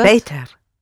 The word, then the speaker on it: beter: Not better, but beter.